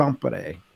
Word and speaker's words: Tampere: And of course, the pronunciation would be Tampere,